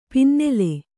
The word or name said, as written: ಪನ್ನಾಳ